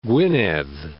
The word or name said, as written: Gwynedd